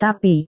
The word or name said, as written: tapi